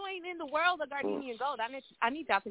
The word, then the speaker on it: Gardenian: It's pronounced Gardenian, y'all. I'm going to spell it for y'all again. G-A-R-D-E-N-I-A-N Gold. Gardenian Gold.